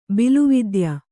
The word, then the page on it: ಬಿಲುವಿದ್ಯ: bilu vidya